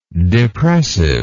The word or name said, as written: depressiveness